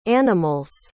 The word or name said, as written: animal's